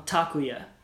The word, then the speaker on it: Takuya: The emphasis on a Japanese name is almost always on the first syllable. So it's Takuya.